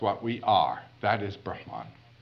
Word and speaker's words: Brahman: The highest caste is called the Brahmin, B-R-A-H-M-A-N, and that first A is a long A. Now that long A means related to, having to do with Brahman, with a short A.